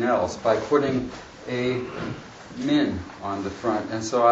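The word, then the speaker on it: min (מן): There's a preposition in Hebrew pronounced min,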